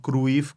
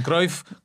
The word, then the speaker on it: Cruyff: Bueno, nuestros mejores deseos para Jordi, que ahí en Israel, en vez de pronunciarlo Cruyff como aquí, lo pronuncian Cruyff como en holandés.